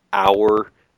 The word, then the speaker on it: hour: Hour, the H is silent, so we say an hour.